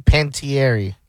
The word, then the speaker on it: Panettiere: But Hayden Panettiere, is that the way you say it? Hayden Panettiere. How would you say it? – Hayden Panettiere. – Panettiere. Panettiere. Panettiere. – Was it with an A? – It ends with an E. Panettiere.